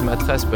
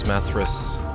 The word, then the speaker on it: Mathres: Well, in French it's Matres, but in English it's Mathres.